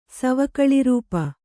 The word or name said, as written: ಸವಕಳಿರೂಪ